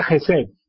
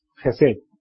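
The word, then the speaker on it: Gesed (חֶסֶד): La palabra misericordia en el hebreo es, se pronuncia Gesed. Gesed.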